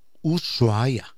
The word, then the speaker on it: Ushuaia: Si você quiser pronunciar bien, coloque seus labios como se fosse beijar. Ushuaia.